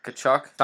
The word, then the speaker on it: Tkachuk: If I'm going to go in that range, I'll save $200 and go to Matthew Tkachuk, if I said that right. – Yeah. Matthew Tkachuk. – Tkachuk, silent letters, man.